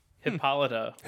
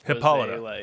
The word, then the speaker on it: Hippolyta: Hippolyta. – Hippolyta. – Thank you. – That's how it's pronounced.